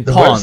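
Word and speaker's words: Pwned: according to Have I Been Pawned's Troy Hunt. – I have been pawned. – The website hosted... – It's pawned. – I've been pawned. – Yeah, that's how you say it, yeah.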